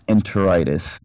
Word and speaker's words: enteritis: So they say, even though the actual report says enteritis, I'm not sure if I'm saying that right. It's enteritis.